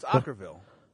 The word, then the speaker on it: Okkervil: O-K-K-E-R-V-I-L River. How would you spell that, Fez? How would you pronounce that? – I would say Okerville. – It can't be right. – It's Okerville.